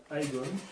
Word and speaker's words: Aegon: Alors déjà, ça se prononce Aegon.